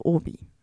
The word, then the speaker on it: Obi: Also Hobby geschrieben, ja. Aber die Franzosen haben ja ein stummes H. – Sehr gut. Und weil Franzosen kein H aussprechen können, übernahmen sie kurzerhand die Lautschrift Obi, also Obi.